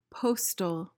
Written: postal

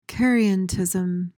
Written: charientism

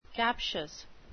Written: captious

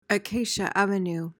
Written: acacia avenue

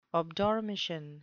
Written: obdormition

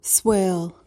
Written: swale

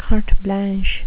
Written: carte blanche

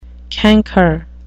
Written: canker

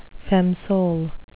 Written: feme sole

